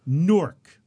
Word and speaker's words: Newark: Newark rhymes with fork. If you're in Delaware, it's Newark. – Right. – Okay? But in New Jersey, we have Newark.